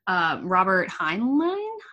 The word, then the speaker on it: Robert Heinlein: Robert Heinlein? – Yeah.